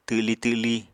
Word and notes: twli twli: Apatani

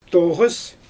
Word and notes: toɣɯs: Xyzyl

